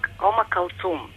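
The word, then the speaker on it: Umm Kulthum: Kutlum, da, veoma. – Jel dobro kažemo? Jel se tako kaže? – Jeste. Omakalcum. – Aha, tako se izgovara, jel? – Jeste. U originalu.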